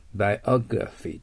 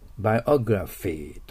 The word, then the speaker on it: biography: biography となります。気をつけるところは、グラが R の発音であること。 PHF の発音であるということですね。 biography と発音しないといけません。